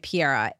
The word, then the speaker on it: Piera: Her name is Piera. I hope I'm pronouncing that correctly. P-I-E-R-A. And her name on Instagram is Hi Piera. H-I-P-I-E-R-A.